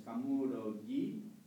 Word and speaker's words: Kamurogi: Esto también es importante pronunciarlo bien. Muchas veces oye Kamurogi, Kamurogi. Es Kamurogi, Kamuromi.